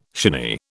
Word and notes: shinny: British English

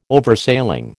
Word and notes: Oversailing: American English

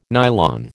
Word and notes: nylon: American English

